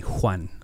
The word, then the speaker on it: Juan: It's Juan. I don't even pronounce it right myself. But if my mom had her way, everybody would call me Juan.